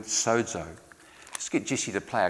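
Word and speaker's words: sozo (σῴζω): That's pronounced sozo.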